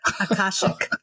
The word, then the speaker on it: Akashic: And I'm sure I didn't pronounce that right. – Akashic. – Akashic. There we go.